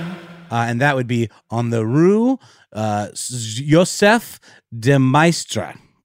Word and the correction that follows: Maistre: Oh, that's, yeah, guys, I butchered that one. M-A-I-S-T-R-E. – Yeah, Joseph de Maistre.